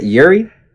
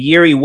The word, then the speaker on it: Jiří: Is that how you pronounce it? Jiří? J-I-R-I? – I don't know. I just like saying Procházka. All right. – It is.